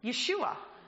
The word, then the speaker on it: Yeshua: But if we were to take that word directly from the Hebrew into the English, we would pronounce it Yeshua.